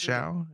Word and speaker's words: Xiao: In comparing the two systems from his latest study, Zhao, which I hope I'm pronouncing that name right, it's X-I-A-O, Xiao? Zhao? What was that? You're muted. – Zhao, I would say is Zhao, yeah.